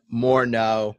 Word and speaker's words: Morneau: Justin Morneau is 100% right.